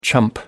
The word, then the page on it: chump: American English